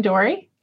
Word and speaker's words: Doree: Hi, I'm Doree.